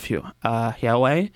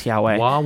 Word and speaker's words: Huawei: The United States had an extradition order out against Meng Wanzhou, who is Huawei's, I think she's the chief financial officer of Huawei. I keep mispronouncing it, but I'm pretty sure it's Huawei. – Huawei. – I'm going to say Huawei.